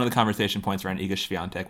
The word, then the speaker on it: Iga Swiatek: Yeah, Iga Swiatek. That's how you say it. Not that hard.